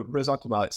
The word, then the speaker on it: ressentiment: Um, please pronounce that correctly and and tell me what you mean by it. – Oh, I, so I'm not a perfect French speaker either, but no, ressentiment. So, um, so this is a word.